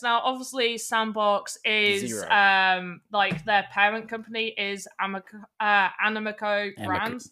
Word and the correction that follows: Animoca: I always say it wrong. Animoca, not Animoco.